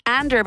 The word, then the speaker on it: Andr: Ander, by the way, is spelled A-N-D-R, and her mini album is called Skin.